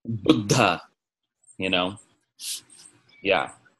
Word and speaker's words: Buddha: But I just remember that story because he always pronounced it very correctly. Buddha, Buddha, you know, with that little pause for the double consonant.